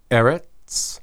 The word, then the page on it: erets: Hebrew